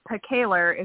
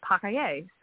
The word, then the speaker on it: Pacailler: And then it's Pacailler is how my husband's family says it, but actually in French is Pacailler. So who knows how to pronounce any of it.